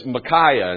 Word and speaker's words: Micaiah: His name is Micaiah, and that's the pronunciation that I'm going to go with,